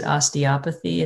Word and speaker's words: osteopathy: So just for people listening, we typically pronounce it osteopathy. It's one of those words that a lot of people have a hard time with, and it's totally fine.